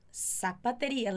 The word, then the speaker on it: zapatería: Zapatería. Lembra que o Z sempre vai ser pronunciado com som de S, hein? Não vai fazer um Z de abelha.